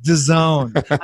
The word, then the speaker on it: DAZN: It's pronounced DAZN.